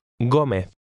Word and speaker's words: Gómez: Did you notice the Castilian pronunciation of her name? Say again, good afternoon, Mrs. Gómez.